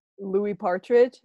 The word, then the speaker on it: Louis Partridge: Oh my God, Louis Partridge.